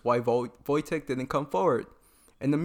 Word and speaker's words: Wojtek: Wojtek. – Wojtek. In my head, I was like, oh, V-O-L-T, whatever.